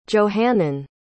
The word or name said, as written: johannine